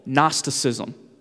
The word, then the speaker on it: Gnosticism: Gnosticism. There's a silent G in front of that.